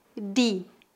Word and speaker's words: de: Acredito que essa seja a pronúncia mais comum no Brasil. Em algumas regiões do Nordeste, as pessoas pronunciam di, di. E em algumas cidades do Sul, as pessoas pronunciam de, de.